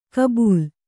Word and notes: ಗಬ್ಬಲು: gabbalu

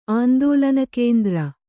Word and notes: ಆಂದೋಲನಕೇಂದ್ರ: kannada

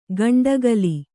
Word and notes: ಗಂಡಕಾಲಿ: gaṇḍakāli

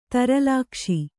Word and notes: ದುರ್ಲಕ್ಷ: durlakṣa

